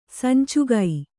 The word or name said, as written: ಸಂಚಿಗೆ